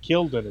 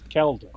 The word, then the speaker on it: Keldon: It's not Keldon, it's Keldon.